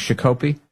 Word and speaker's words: Shakopee: is Shakopee is the name, around Christmas.